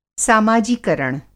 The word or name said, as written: सामाजीकरण